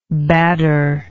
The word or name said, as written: badder